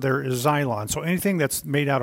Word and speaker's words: xylon: The Greek word there for cross, it's xylon, X-Y-L-O-N.